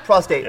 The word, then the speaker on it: prostate: It's pronounced prostate.